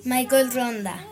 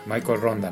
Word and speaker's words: Michael Ronda: Y Michelle Ronda. – Michael Ronda. – Ah, Michael Ronda.